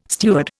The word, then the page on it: steward: Indian